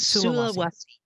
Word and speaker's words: Sulawesi: Pronounce it for me one more time. – It's Sulawesi. Right. Sulawesi.